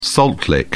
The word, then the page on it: salt lick: British English